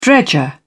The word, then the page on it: dredger: British English